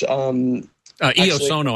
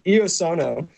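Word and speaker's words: Iosono: There's a company called Ionosound, which Eosono, I think, is what you're thinking. – I'm sorry, I mispronounced it. Eosono,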